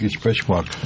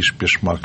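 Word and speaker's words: өчпочмак: Но гость уверяет нас, что правильно его произносили, как Ичпачмак, а не Ишпешмак, как нам пишет один из слушателей.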